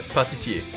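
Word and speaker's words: pâtissier: Am I pronouncing that word right? Like, I feel like I'm pronouncing that word right. – Yeah, patissier.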